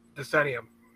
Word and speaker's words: Decennium: The TMNT, Decennium, Decennium. – Decennium, okay.